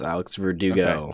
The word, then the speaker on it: Alex Verdugo: Am I pronouncing that first one right? – Yeah, you got, yes, Alex Verdugo.